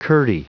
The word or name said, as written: curdy